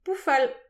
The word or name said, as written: poufal